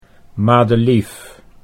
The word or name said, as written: Madelief